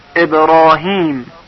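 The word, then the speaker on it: إبراهيم: یک الف مقصوره گذاشته شده، یعنی با صدای کشیده بالا تلفظ بشود. ابراهیم، ابرا، را.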